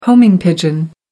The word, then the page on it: homing pigeon: American English